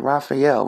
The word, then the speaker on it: Raphael: You know, Raph, I'm not pronouncing that name right. Raphael, Raphael Warnock. There we go.